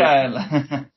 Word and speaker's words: AEL: Yeah, we say AEL.